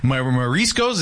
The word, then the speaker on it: mariscos: because I would love to go get some mariscos. Is that how you say it? – Yeah.